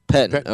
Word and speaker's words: Pettine: Pettine. – Pettine.